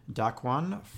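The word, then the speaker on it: Dakk'One: Dark One from Vancouver. So that name is spelled D-A-K-K apostrophe O-N-E.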